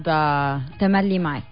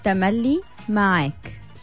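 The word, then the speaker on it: Tamally Maak: It's Amr Dieb and Cheb Khaled, and it's called Tamali Mike. – Tamali Mike. – Yeah. – Did I say it right? – Somehow.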